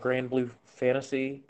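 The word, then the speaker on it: Granblue Fantasy: I've been playing a lot of Grand Blue Fantasy.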